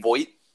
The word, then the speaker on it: Voit: Like Luke Voit? That's how you say it, Voit.